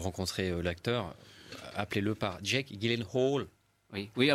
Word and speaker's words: Gyllenhaal: Gyllenhaal. Oui, je me souviens de ça. Euh, c'est c'est Gyllenhaal.